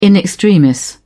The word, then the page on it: in extremis: British English